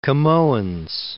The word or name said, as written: Camoens